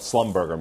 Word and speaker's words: Schlumberger: Uh, in terms of Slumberger, you mentioned it, you pronounced it much better than I, but you know, in the business, we used to call it slumberger.